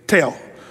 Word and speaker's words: tel: The Hebrew word for that is tel, or as the Hebrew, it's spelled like T-E-L, but the Hebrew people would say tail.